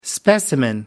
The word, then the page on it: specimen: American English